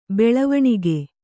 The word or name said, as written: ಬೆಳವಣಿಗೆ